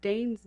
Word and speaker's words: Danes: And yes, it's Danes, D-A-N-E-S, Anna Danes.